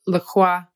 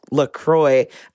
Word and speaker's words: LaCroix: Although he pronounces it LaCroix. – You know, I for the longest time, I called it LaCroix too. And I got really irritated when people were like, it's LaCroix. I was like, shut your filthy mouth. It's French. It's LaCroix. – Yeah, that's exactly how you pronounce it in French. It'd be LaCroix.